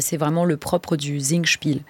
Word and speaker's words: Singspiel: Un Singspiel, oui, ça se sous-entend.